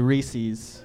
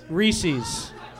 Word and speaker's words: Reese's: Reese's. – Reese's. Like the Reese's monkey.